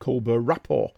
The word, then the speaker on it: The Colbert Report: In 2004, he spun off into The Colbert Report, pronounced Colbert Rapport.